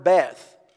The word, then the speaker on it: Beth: It's the word Beth. We would pronounce it as if we saw it in English, B-E-T-H, we would pronounce it that way. But it's actually pronounced Bet.